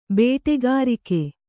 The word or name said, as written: ಬೇಟೆಗಾರಿಕೆ